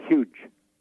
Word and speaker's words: hygge: And they have a word for this in their country, and it's spelled H-Y-G-G-E, pronounced huge.